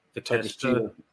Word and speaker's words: Takeshita: Takeshita. – Yeah. – What do you think, Damone? – I feel like I think for this, yeah, you have Takeshita like retain.